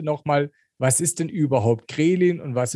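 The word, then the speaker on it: Ghrelin: Also, das heißt natürlich Grelin, aber ich konnte es nicht aussprechen, deswegen war mir irgendwie Kremlin, ja.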